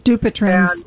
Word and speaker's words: Dupuytren's: So actually, my question is, I've started to develop a Dupuytren's, I can never pronounce it properly, contracture in my hand. – Dupuytren's. – Yeah, thank you.